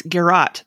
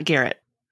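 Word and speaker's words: garrote: It's Garrot, not Garrett. – Not Garrett the person? – So, because I was explaining it to Thomas.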